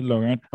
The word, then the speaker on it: LaRont: Today we have Marcy LaRont. Am I saying that right? Marcy LaRont? – LaRont. – Is that French by any chance? – It is.